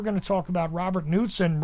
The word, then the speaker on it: Knudsen: It's spelled K-N-U-D-S-E-N. I often say Knudsen because it seems like the logical way to pronounce it, but right.